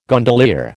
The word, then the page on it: gondolier: American English